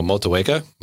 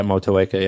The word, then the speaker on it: Motueka: Muteka. I don't know how to pronounce it, but oh, hang on. Oh, Motueka. Yeah, there we go. – Yeah, Motueka. Yeah.